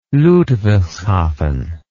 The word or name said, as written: Ludwigshafen